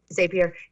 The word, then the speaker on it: Zapier: So let's start with Zapier, or if I'm pronouncing that correctly. – Zapier.